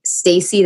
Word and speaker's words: Stacie: It's at Stacey Theotmus.